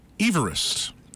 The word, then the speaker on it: Everest: And finally, Sir George Everest. That is how he pronounces his name. And that is how the mountain would be pronounced.